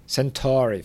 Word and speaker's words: Centauri: Alex tells a man who calls himself Centuri. Is it Centuri or Centuri? I can't remember how they say it. – Centauri, thank you.